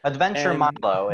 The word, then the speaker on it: Adventure Milo: Adventure Milo is the easy way to pronounce it.